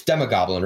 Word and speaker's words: Demogoblin: It's Demogoblin, right? – Yes.